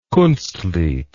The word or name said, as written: kunstlied